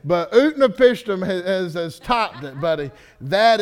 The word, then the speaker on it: Utnapishtim: Utnapishtim. Utnapishtim.